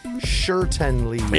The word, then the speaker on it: Schertenlieb: Schertenlieb.